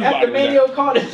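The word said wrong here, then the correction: Maino: How you say his name? Mano. – Mano? – Just think about it. M-A-N-O. Just Mano. Act like the I ain't there. No I in Mano.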